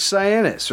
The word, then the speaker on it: Sianis: How do you say that? – William George Cyanus, right here. – All right, all right. How do you spell Cyanus? – S-I-A-N-I-S.